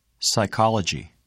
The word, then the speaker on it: psychology: The letter P is silent in the following words. Repeat them during the pauses. – Receipt. Psychology.